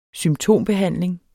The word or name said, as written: symptombehandling